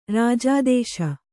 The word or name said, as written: ರಾಜಾದೇಶ